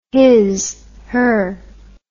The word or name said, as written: his/her